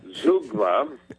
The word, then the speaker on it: zeugma: Zugma. – Zugma. – Prepare yourself. Sharpen your pencil. – All right. – Sharpen your ears. The word of the day is Z-E-U-G-M-A. Zugma, it is pronounced. Z-E-U-G-M-A.